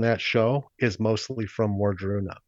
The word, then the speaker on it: Wardruna: Wadruna. – Oh, yeah. – Can you spell that? – And what they do is Wardruna. – Wardruna. – Wardruna.